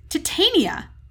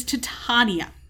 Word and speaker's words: Titania: According to Polly's Shakespeare scholar father, it is Titania as opposed to his Titania. I can't tell. I'm reading this. I can't tell if it's supposed to be Titania or Titania.